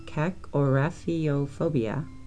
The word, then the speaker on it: kakorrhaphiophobia: It is pronounced cacorafiophobia. It is spelled K-A-K-O-R-R-H-A-P-H-I-O-P-H-O-B-I-A.